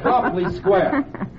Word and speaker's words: Copley Square: All right, folks, we're ready to leave Copley Square, pronounced Copley, not Copley. After all, you wouldn't call a cop a cope, would you? So we say Copley Square.